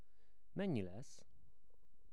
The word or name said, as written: Mennyi lesz